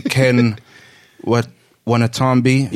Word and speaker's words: Ken Watanabe: I'm very disappointed in Ken Watanabe.